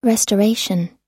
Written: restoration

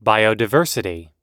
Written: biodiversity

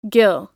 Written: gill

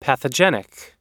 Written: pathogenic

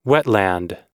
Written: wetland

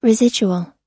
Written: residuals

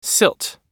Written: silt

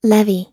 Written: levy

